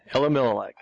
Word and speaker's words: Elimelech: The man's name was Elimelech. Took me a while to pronounce that one. Elimelech.